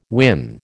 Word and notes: win: American English

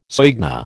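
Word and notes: soigné: American English

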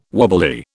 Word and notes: wobbly: British English

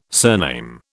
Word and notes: surname: British English

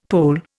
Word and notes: Pole: Indian

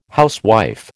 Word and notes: housewife: American English

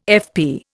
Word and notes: FP: Indian

